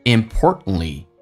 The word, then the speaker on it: importantly: 两个 t 都不发音，t 跟 n 在一起，t 是不发音，t 跟 l 在一起组合，t 也不发音，就变成 importantly and more importantly。